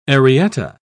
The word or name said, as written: arietta